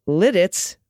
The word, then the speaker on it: Lititz: Uh, Lititz was said perfectly, and yes.